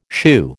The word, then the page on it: shoo: American English